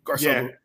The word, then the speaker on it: Garcelle: I was going to say fancy, but Garcelle. Yeah, you don't need me. You don't need my help for fancy. Yeah, you got that. Yes. You mm-hmm. You pronounce that very well.